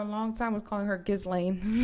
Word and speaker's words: Ghislaine: Okay, so that lady, Ghislaine Maxwell. – Ghislaine. – How do you say her name? – So it's Ghislaine. – Ghislaine? – Ghislaine, like Glenn, which is weird.